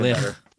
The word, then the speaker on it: Bleick: It's spelled B-L-E-I-C-K, or C-H. – Okay, even better. Even Blick.